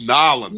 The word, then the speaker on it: New Orleans: And then I used to pronounce it New Orleans. – It's Nolens. – Yeah, Nolens, exactly. And then I have family in Louisiana.